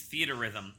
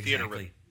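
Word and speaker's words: Theatrhythm: It's Theater Rhythm. They actually, because they had a lot of people saying it different ways, they posted on their website the video of what how they actually pronounce the game.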